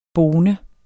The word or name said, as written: bone